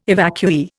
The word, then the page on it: evacuee: Indian